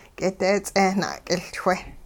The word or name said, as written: k'ideets'eh na'k'iLchwe